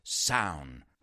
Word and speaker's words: Samhain: Now, this is really weird because it's Samhain, if you see it written, but it's pronounced Samhain.